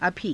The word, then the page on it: abhii: Hindi